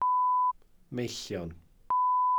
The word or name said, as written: meillion